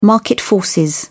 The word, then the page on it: market forces: British English